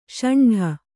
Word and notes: ಷಂಡ: ṣaṇḍa

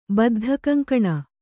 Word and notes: ಬದ್ಧಕಂಕಣ: Kannada

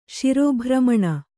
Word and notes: ಶಿರೋಭ್ರಮಣ: śirō bhramaṇa

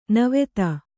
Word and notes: ನಿವಾತ: nivāta

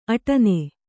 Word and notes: ಆಟೀನ್: āṭīn

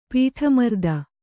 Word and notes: ಪೀಠಮರ್ದ: kannada

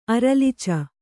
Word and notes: ಅರಲ್ಚು: kannada

